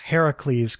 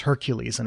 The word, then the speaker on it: Heracles: Oh, I was just going to say, I'm glad that you pronounced Heracles, because I read that as Hercules, and it's clearly a different word.